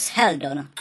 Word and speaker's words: Sheldon: Ten Sheldon se píše s Heldon. – No to já vím, ale čte se to Sheldon.